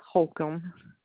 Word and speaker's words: Holcomb: Cindy Holcomb. – Spell that last name. – H-O-L-C-O-M-B.